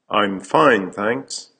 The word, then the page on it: I'm fine, thanks: American English